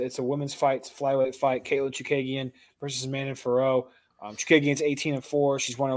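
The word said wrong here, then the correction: Fiorot: Manon Farrow, or how do you say it, is... – Just Firo.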